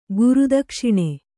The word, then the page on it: ಗುರುದಕ್ಷಿಣೆ: guru dakṣiṇe